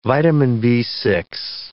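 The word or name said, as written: vitamin B6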